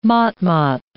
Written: motmot

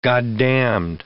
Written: goddamned

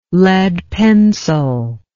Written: lead pencil

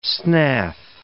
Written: snath

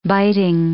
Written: biting

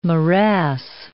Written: morass

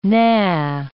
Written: nah

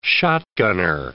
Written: shotgunner

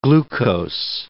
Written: glucose

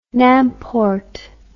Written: n'importe

